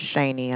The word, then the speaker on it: Shaney: My name is Shani, and I spell that S-H-A-N-E-Y.